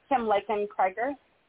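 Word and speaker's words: Lakin-Creger: Kim Laken-Krieger. – Kim, L-A-K-E-N. – A-I-N. And then C-R-E. Yeah, L-A-K-I-N. And then C-R-E-G-E-R.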